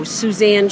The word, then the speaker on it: Suzanne: Oh, Suzanne Shanley from the Agape community.